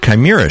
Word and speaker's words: chimera: Your aim, steady, and chimera shots causes the target to bleed for 10, 20, or 30% of damage dealt for 8 seconds.